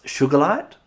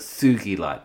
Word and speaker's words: sugilite: Sometimes pronounced sugarlite, but it's sugilite. It was discovered by a Japanese petrologist called Kenichi Sugi.